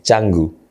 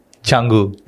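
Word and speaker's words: Canggu: We say Indonesian, we say like Changu. – Changu. – Yeah, it's right.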